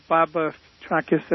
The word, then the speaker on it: Petrakis: Yeah, Bob Petrakis. That's the Greek pronunciation.